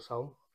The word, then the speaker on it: Saul: So, Cesar, it's pronounced in Romanian, Virtuoso, which is V-I-R-T-O-S-U. And the creative director, but I'm not, unfortunately. – So my name is Saul Gascon, G-A-S-C-O-N, and Saul, I better call Saul.